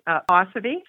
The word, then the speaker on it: Posivy: Posivy.